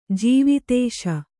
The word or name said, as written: ಜೀವಿತೇಶ